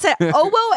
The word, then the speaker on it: OWO: O-W-O. – O-W-O? – That's definitely what I mean. Okay. If you pronounce it, but it's all caps, so it's got to be O-W-O, right? – It's O-W-O. – It's O-W-O and Ubisoft have partnered to bring physical sensations to Ubisoft's game portfolio.